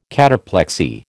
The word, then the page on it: cataplexy: American English